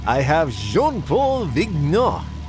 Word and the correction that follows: Vignon: is that how you pronounce it? Vignon. Okay, and Farrah Fawcett together making what's the equivalent of a perfume commercial, I think.